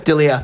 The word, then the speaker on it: D'Elia: D'Elia.